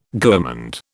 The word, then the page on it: gourmand: British English